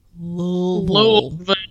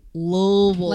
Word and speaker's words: Louisville: He's going to Louisville on Monday. – Yeah. – I'm sorry, I mispronounced it. Louisville. – I love you both. – Louisville. – Like you have a mouth full of shit.